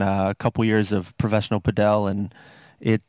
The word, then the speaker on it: padel: where you used to play the padel. I don't know if I'm saying that right. – You are, yeah.